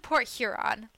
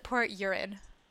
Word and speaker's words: Port Huron: My grandparents live in Port Huron. Like, okay. Huron, like, Lake Huron. Port Huron. But everyone there pronounces it Port Urin.